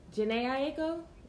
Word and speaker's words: Jhené Aiko: First things first, Jhené Aiko, is that how you say it? – Yes.